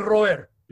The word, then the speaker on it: Robert: Robert. – But if you're speaking Spanish. – I know. – Spanish speaker would say, él se llama Luis Roer.